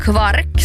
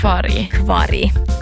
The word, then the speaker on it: kvark: Och sen då mjölkprodukter med omstritt uttal kvark, som ju också många uttalar som kvarg. Jo, det fattar jag inte. – Nej, jag fattar det. Alltså sluta genast. – Jo. – Varför? – Bra fråga, Joel. Varför? Nu ställer du de viktiga frågorna. – Nu. – Varför ska man uttala kvark som kvarg?